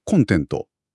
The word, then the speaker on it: コンテント: じゃあまずはよく耳にする方の名詞のコンテント、コンテンツ、前にアクセントが来る方から見ていきましょうか。